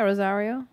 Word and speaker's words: Rosario: Anyway, Rosario Dawson, is that how you say her name? – Yeah, Rosario.